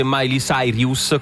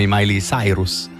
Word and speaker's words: Miley Cyrus: Che tra l'altro ci hanno scritto, non si dice Miley Cyrus, come ho detto io. Perché non, ha cambiato pronuncia? – No, si chiama Miley Cyrus.